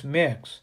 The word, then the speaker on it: Mx: Yes, there is a new genderless honorific term, MX, pronounced mix, but that goes back to titles, which I also don't use.